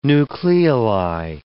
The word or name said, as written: nucleoli